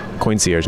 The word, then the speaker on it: Coincierge: Coincierge, not concierge.